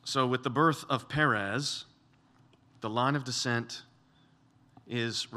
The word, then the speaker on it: Perez: And in Hebrew, this word breach it sounds like the word Perez or Paraz. Therefore, his name was called Perez.